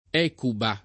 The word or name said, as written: Ecuba